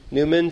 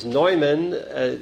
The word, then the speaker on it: Neumann: St. John Newman, 3 hours up the road in Philadelphia. The actual pronunciation is Neumann. He's from Bohemia, but up in Philadelphia and most of America, they call him St. John Newman.